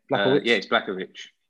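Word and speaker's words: Błachowicz: UFC 259, Blachowicz, how do you say it? – Yeah. Blachowicz. Yeah.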